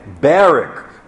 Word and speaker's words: Barack: His father, Barack Hussein Obama, who became senior, and that's how he pronounced his first name, Barack, with the British accent that he had, was a Kenyan from the Luo tribe, an economist who became a government bureaucrat.